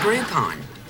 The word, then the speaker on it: Groupon: What's a Groupon? – Oh, for God's sake, Jill. Rhymes with coupon, has group in it.